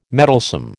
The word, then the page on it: meddlesome: American English